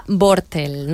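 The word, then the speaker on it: Bortle: Tenemos herramientas, por ejemplo, la escala Bortel.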